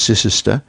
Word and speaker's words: Cirencester: Whereas in English, Worcestershire is, you know, Worcestershire, and Cirencester is Cicester, and the English have a tradition.